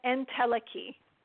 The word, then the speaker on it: entelechy: Tell me if I'm pronouncing this right. Intelligy. – And what is that? And go ahead. – Oh, entelechy.